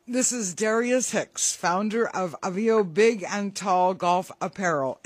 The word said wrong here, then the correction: Aveo: Am I pronouncing it correctly? – close. Aveo. – Aveo. There we go.